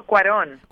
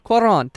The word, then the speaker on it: Cuarón: I always butcher his name. I apologize. Cuarón Gravity, for the film. – Alfonso Cuarón. – Cuarón. Thank you. Thank you for that.